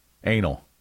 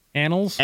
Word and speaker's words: annals: A-W-R-Y. – Awry. – Awry. Not Ori. Not Ari? – No. A-N-N-A-L-S? – Anal. – Annals? – Annals.